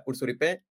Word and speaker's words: Hourcouripe: Ella es Florencia Ursuripe.